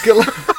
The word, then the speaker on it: Guillermo: It's Guillermo. Guillermo. That's how you say it. – You don't pronounce the L, man.